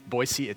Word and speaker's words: Boise: If you ever go to Boise, what you consider Boise is actually in Boise. It's Boise.